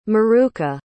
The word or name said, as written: maruca